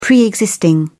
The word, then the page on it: pre-existing: British English